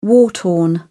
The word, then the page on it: war-torn: British English